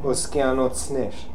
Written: Oskianotsner

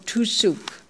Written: Toosook

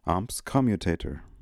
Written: ams commutator

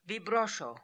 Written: Vibrosho

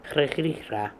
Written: Hre Hree Hra

